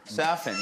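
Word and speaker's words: Safin: Is it pronounced Lucifer Saffin? – Lucifer Saffin, yeah.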